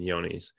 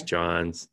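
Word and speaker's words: Johne's: Typically OPP, CL, and yonis. – What was the last one? – Yonis? Yes, or, everybody pronounces it a little differently. Yonis, okay. Johns, whatever.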